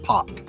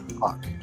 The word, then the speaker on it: Paq: I thought we pronounced it Pak. – Pak, excuse me. – Pak. Still spelled P-A-Q, but Pak.